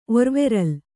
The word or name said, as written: ಒರ್ವೆರಲ್